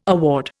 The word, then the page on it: award: Indian